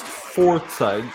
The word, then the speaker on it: Forza: It's pronounced Forza. Take it from the top. Tonight, you messed it up.